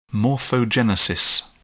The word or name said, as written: morphogenesis